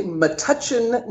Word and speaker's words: Metuchen: So Ruegger was born in Metuchen, New Jersey. Am I pronouncing that correctly? – It's actually Metuchen, New Jersey.